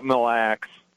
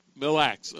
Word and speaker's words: Mille Lacs: Is it Mill Lacs Lake, or how is it pronounced? – They call it Mille Lacs. – Mille Lacs, okay.